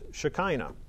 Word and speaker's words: Shekinah: Shekinah.